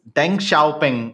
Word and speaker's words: Deng Xiaoping: it was Deng Xiaoping. I said the name wrong. I said Xiao Tianping.